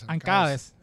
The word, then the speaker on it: Ancadez: Es que mira, el nombre de nuestro artista invitado es Ancades, ¿verdad? O Ancades. – Ancades.